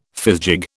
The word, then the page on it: fizgig: British English